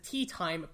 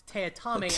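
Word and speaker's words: Teatime: When you have a villain of a book be named Mr. Tea Time, but it's pronounced Teotame, he will remind you every fucking time it's pronounced that way.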